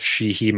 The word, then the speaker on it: Sheehey: So personally, I'm on Instagram at Joseph Sheehey.